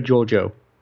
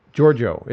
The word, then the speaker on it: Georgiou: Philippa Georgiou. – Georgiou is how you pronounce her last name.